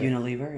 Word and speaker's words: Unilever: Unilever, yeah. – Unilever? – It depends on how you say the word lever. Lever.